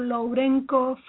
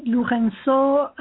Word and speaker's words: Lourenço: Well, it could be Lorenzo or Lorenzo or Lourenco. French people say Lourenço, and in other countries they say Lourenco. So it's okay.